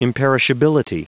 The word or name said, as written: imperishability